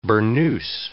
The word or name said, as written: burnoose